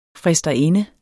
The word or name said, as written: fristerinde